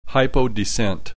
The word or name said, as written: hypodescent